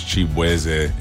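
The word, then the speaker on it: Chibueze: My last name is actually pronounced Chibweze.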